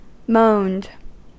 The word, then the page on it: moaned: American English